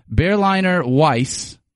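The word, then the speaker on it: Berliner Weisse: Second one is Maddie Full of Berries, a fruity and crispy Berliner Weiss. Is that how you say it? I don't know. – Close enough, yeah.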